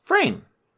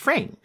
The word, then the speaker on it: Frayne: My last name is Frayne. It's Frayne. It's F-R-A-Y-N-E.